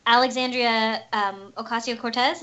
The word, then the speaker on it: Alexandria Ocasio-Cortez: Oh, also, total tangent, I don't know how to say her name, so I apologize, but Alexandria Ocasio-Cortez, is that how you say it? – Yeah, yeah.